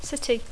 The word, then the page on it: city: English